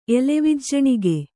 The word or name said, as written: ಎಲೆವಿಜ್ಜಣಿಗೆ